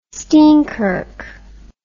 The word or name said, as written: steenkirk